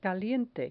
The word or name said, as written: caliente